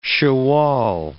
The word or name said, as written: Shawwal